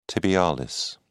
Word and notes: tibialis: British English

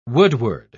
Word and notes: woodward: British English

